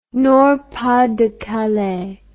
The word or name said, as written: Nord-Pas-de-Calais